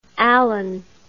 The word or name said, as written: alleyn